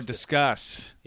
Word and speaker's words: Disqus: We started using Disqus, D-I-S-Q-U-S. I put it into place. – Is that how you pronounce it? For some reason, I thought it was disc, like discuss, but... – Oh, discuss. Yeah, you're right. Except it's with a Q.